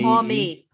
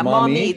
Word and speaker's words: Maumee: We're open Maumee. I'm not sure how to say it. Maumee, M-A-U-M-E. – Maumee. Yeah, Maumee.